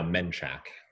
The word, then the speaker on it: Manchac: Well, now Menchac. – Yes. – I have to pronounce it correctly.